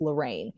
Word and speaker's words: Lorriane: And I stopped her and I was like, you know, I've just got to say my name is pronounced Lorraine.